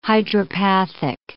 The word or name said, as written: hydropathic